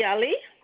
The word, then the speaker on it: Ali: Hi, my name is Bibi Ali.